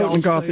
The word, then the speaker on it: Totenkopf: Totenkopf, yeah.